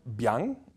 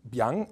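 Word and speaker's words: biang (𰻞): The most complex Chinese character I have ever encountered, pronounced biang, biang, and it refers to specific noodles.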